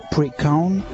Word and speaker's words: Leprechaun: Le nom de ces petites créatures, issues du folklore irlandais, se prononce approximativement les Leprechaun en gaélique.